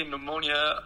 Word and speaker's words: pneumonia: Yeah, but you don't pronounce the P in English. – Okay, pneumonia, or...